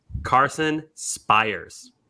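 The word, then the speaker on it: Carson Spiers: Gordo, I got the pronunciation. It is. – Okay, what is it? – Carson Spires. – Okay, Spires, like Mike Fiers. – Spires on the Mariners.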